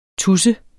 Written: TUSSEN

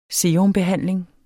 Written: serumbehandling

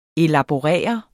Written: elaborere

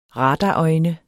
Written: radarøjne

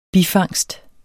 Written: bifangst

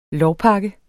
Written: lovpakke

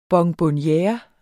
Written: bonbonniere

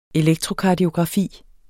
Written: elektrokardiografi

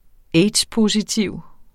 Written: aids-positiv

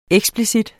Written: eksplicit

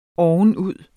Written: ovenud

